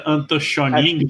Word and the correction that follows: Astonishing: É Astonishing.